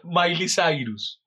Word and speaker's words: Miley Cyrus: Eh, Miria Cyrus. ¿Es así? ¿O Mila Cyrus? siempre confundo el nombre. ¿Cómo se dice verdad? Miley Cyrus. Miley Cyrus. Sí, sí. Miley Cyrus.